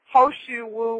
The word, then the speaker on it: He Shou Wu: So if some people who are losing hair or having premature graying of the hair, Hoshu Wu, which is really, it looks like it's pronounced Hishu Wu, because Hoshu Wu is spelled H-E-S-H-O-U-W-U, and it looks like Hishu Wu, but it's pronounced Hoshu Wu, and it's very, very good.